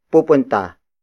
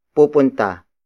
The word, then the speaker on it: pupunta: Word for word it's, pupunta, kami, sa, mall, mamaya. Pupunta, kami, sa, mall, mamaya.